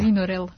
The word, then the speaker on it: Vinorel: Imamo priliku da razgovaramo sa akademskom slikarkom, koja se bavi jednom neobičnom tehnikom koja se zove vinakril, ako sam ja dobro izgovorila. – Vinorel. – Vinorel, eto, znala sam da sam pogrešila.